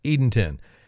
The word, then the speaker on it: Edenton: But this time, I figured I'd find out how the locals pronounce it. – Thank you for calling Visit Edenton. To help us reduce spam calls, please press 1 to reach the front desk. – So there you go. It's Edenton.